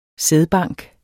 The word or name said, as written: sædbank